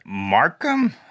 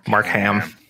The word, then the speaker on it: Markham: Markham. – You just Mark Ham. It's like Mark Ham. – Mark Ham. Yep. It's exactly like Mark Ham. Yes, without the L.